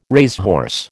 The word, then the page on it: racehorse: American English